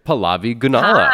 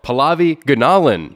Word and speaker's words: Pallavi Gunalan: Pallavi Gunalan. – Hi. – No. – Nope. – I'm so sorry. I said it wrong. Pallavi Gunalan.